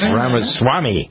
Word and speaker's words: Ramaswamy: and Jennifer was telling me, no, it's this way. It's Ramaswamy.